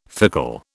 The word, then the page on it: fickle: British English